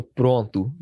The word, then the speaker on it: pronto: But in Portuguese, you would say, eu estou pronto, but it's like a U sound at the end.